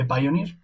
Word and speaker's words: Pioneer: Y digo ya Pioneer porque Rafa hizo una encuesta en Twitter de cómo se pronuncia. Esto es una democracia y la mayoría ha dicho que se dice Pioneer, así que yo no voy a ir en contra de la voluntad de la gente.